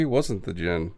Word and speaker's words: djinn: The D is silent, just like Connery's djinn, okay.